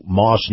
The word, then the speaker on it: Maus: Army, brought to you by our friends at Moss Nissan. Moss is spelled M-A-U-S.